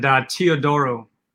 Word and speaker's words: Teodoro: So it's Marson, which is Polish, and Teodoro, which is actually Greek.